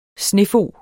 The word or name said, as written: snefog